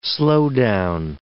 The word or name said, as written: slowdown